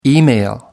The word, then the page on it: email: American English